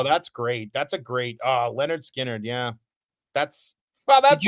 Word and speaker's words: Lynyrd Skynyrd: Pronounced Lynyrd Skynyrd. They tell you right there how to say it.